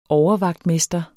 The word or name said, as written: overvagtmester